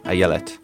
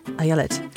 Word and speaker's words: Ayelet: Ayelet. – Ayelet. It's a lovely name. – It is a great name. And why did you want to talk to her? – So I wanted to talk to Ayelet Waldman because, well, there are a couple reasons.